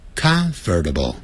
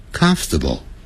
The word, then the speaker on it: comfortable: 不要念成 comfortable, comfortable. 相信我老外就是这样念的。